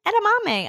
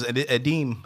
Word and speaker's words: edamame: I'm eating edamame. I was like, I didn't even know how to say this shit. – Right. I thought it was Edeem.